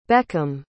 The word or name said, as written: beckham